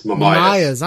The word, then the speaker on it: Mamais: Is it Mamayas? – Mamayas.